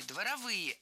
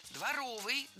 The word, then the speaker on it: дворовый: Дворовых соревнований, дворовые – это неверно. Правильный вариант – дворовый, дворовые соревнования. Прилагательное, образованное от существительного «двор» – дворовый, с ударением на втором слоге и окончанием «ый». Других вариантов словари не фиксируют, так что дворовые соревнования.